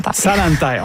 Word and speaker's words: Zalantay: Zalantay. – On va. Je vais noter ça comme il faut. – Dites-le comme il faut, là, pour l'apprendre. – Zalantay. On ne prononce pas le Z.